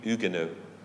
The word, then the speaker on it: Huguenots: 1621, these faithful French Christians, the Huguenots, did I say it right?